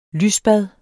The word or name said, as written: lysbad